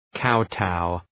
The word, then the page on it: kowtow: ‘kaʋtaʋ